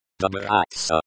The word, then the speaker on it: Добраться: Добраться. – Stress on the second syllable. Do rhymes with do. Bra rhymes with bra. Tsa rhymes with tsa. And ya is like the ya in yacht.